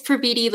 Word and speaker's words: Praviti: It's Praviti like graffiti.